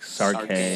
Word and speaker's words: Sarke: It's like Sarkay.